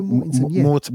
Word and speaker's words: Viggo Mortensen: Viggo Mortensen, is that how you pronounce his name? – Yeah, something like that, yeah. – Viggo Mortensen, Mortensen, yeah.